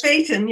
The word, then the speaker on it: Phaeton: It's one of the many names for a horse-drawn vehicle, like, how do you pronounce Phaeton? Is that about right? – PH? Phaeton, yes.